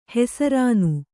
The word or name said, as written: ಹೆಸರಾನು